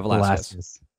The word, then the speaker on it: Velasquez: You said Vasquez. – Oh, yeah, Velasquez. – Just left out a syllable.